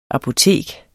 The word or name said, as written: apotek